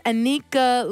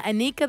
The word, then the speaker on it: Anika: Hey, my name is Anika Luna. Anika, that rhymes with sneaker.